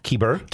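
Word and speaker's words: Kyber: Kieber.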